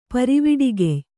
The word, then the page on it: ಪರಿವಿಡಿಗೆಯ್: pariviḍigey